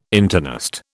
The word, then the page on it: internist: British English